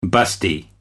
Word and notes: busty: American English

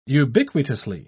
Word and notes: ubiquitously: British English

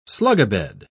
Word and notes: slugabed: British English

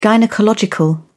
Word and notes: gynaecological: British English

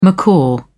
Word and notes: macaw: British English

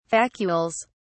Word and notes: vacuoles: British English

